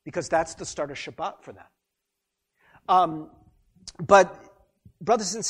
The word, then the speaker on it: Shabbat: But the Sabbath, Shabbat, as it's pronounced in Hebrew, actually means Saturday.